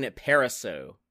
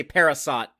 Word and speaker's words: Parisot: And tonight we are talking about the 1999 film Galaxy Quest, written by David Howard and Robert Gordon, and directed by Dean Parisot. It's a silent T on the end. Don't say Parasot, y'all. That's wrong. That's incorrect.